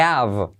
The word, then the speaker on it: have: I have, salto la H, I have to run.